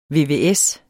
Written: vvs

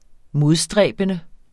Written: modstræbende

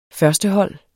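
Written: førstehold

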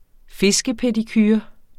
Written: fiskepedicure

